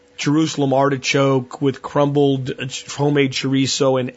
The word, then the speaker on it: chorizo: The spelling, C-H-O-R-I-Z-O. This is a Spanish word, and it's pronounced chorizo with a T-H, chorizo. And those of you golf fans that may have heard of a Spanish golfer back in the day, he was a Masters champion, Jose Maria Olazabal. He had a Z in his name. So that's how this word tends to be pronounced.